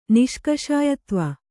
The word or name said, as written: ನಿಷ್ಕಷಾಯತ್ವ